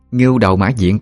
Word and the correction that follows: ngưu: cụ ơi, đọc là ngưu chứ không phải là ngu đâu.